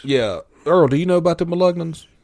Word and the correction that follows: Melungeon: I'm saying it wrong, I think. But apparently, if it were pronounced correctly, I might know what you're talking about. – Melungeon? – Melungeon. – Melungeon. – Melungeon. – Yes.